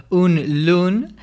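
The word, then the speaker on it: Unge lovende: En danés eh, se escribe Ángel Vende, pero se pronuncia, según he consultado, Un Lun.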